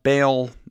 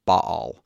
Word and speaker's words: Baal: You could call this god Baal. That's the typical way of pronouncing it, but it would be in Hebrew Baal.